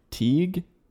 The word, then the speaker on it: Teague: He is the dad, and he's a successful real estate agent who works for a firm headed by Teague, spelled like league with a T.